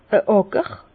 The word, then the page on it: a Àcker: Bas-Rhin; Herrlisheim